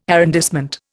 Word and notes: arrondissement: Indian

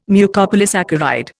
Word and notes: Mucopolysaccharide: Indian

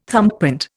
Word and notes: thumbprint: Indian

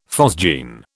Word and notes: phosgene: British English

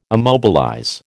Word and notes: immobilize: American English

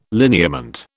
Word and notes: lineament: British English

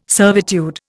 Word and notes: servitude: Indian

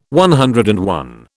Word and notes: CI: British English

